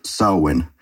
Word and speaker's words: Samhain: May I be the bitch and point out that it's actually pronounced Samhain, as every smartass wants to say, but continue.